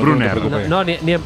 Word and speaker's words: Brunner: Ahí no tiene problema en pronunciar. – Brunner.